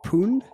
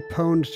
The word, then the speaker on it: pwned: Is that how you pronounce pooned? – I don't know. Pooned? Is that what you would say? – I always say pwned to rhyme with owned.